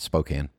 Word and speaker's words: Spokane: And then Tor from Spokane, which is spelled Spokane. Yeah, I know, it's Spokane.